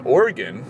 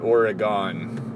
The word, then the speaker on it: Oregon: But I was up in Oregon. Notice how I said that? I feel pretty cool when I do that. That's how the locals say that. Learned that from the old Kugel. It's not Oregon.